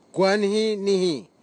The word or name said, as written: kwanhinihi